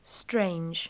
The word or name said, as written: strange